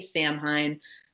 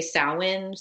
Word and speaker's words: Samhain: This is pronounced so many different ways. I say Samhain. Some people say Samhain. Some people say Samhain. Again, because it's in so many different cultures, there's so many different. I think you say Samhain. Is that how you say it? – Samhain. Yeah, I say Samhain. Yep.